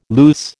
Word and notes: luce: American English

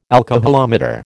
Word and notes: alcoholometer: American English

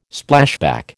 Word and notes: Splashback: American English

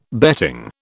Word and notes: betting: British English

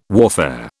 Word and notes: warfare: British English

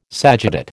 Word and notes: sagittate: American English

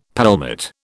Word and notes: pelmet: British English

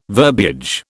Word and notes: verbiage: British English

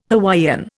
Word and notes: Hawaiian: Indian

